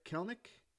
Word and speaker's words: Kelenic: Um, another interesting notes that I thought: uh, Jarred Kelenic and Chris Sale to the Braves. – Yes. First of all, good job pronouncing his name. Oh, ah, thank you, you. you. nailed it.